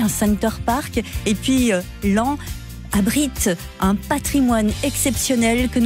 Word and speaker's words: Laon: à Laon, mais ça s'écrit Laon, mais c'est à Laon,